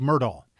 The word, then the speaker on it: Murdaugh: But at any rate, but Murdaugh is how us Murdaugh. Southerners okay. pronounce that name. So it is Murdaugh.